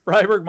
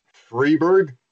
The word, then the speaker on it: Freiburg: Freiburg minus one. – You know, I hate Freiburg. And honestly, I'm glad all your players are coming back. – It's pronounced Freiburg.